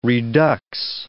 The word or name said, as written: redux